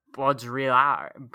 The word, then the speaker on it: Baudrillard: It's French. It's French, so that's not even remotely how you pronounce it. Jean Baudrillard.